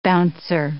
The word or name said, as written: bouncer